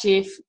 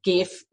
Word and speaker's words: GIF: Number five, GIF off, which is pronounced GIF, but I still say GIF with a hard G and it drives my husband insane.